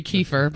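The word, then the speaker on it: Kiefer: Kefir Sutherland. – Yeah. – Yeah. – That'd be Kefir, but you're close.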